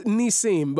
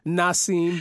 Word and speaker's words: Nissim: No, it's Nissim. But what you know, I answer to Nissim. You know, in the hood, people can't really pronounce it, so they call me Nassim.